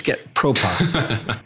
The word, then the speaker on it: getpropa: And I mispronounced it and you're giving me the glare, but it's getpropa.